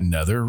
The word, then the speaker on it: nether: Your neither regions? – Yeah. Neither regions. I think we pronounce that nether regions.